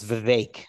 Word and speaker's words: Vivek: It's Vivek, actually. – Vivek. Okay. – Yes. – Yeah. – My friends say you can bake a Vivek cake. – Vivek. – So, yeah. Rhymes with bacon cake.